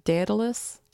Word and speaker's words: Daedalus: D-A-E-D-A-L-U-S. Daedalus? – Daedalus.